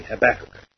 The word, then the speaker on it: Habakkuk: How do you pronounce that here? Do you say Habakkuk or do you say Habakkuk? Okay, Habakkuk. Habakkuk. In fact, that's correct as far as how it looks to be translated here, or pronounced, I should say.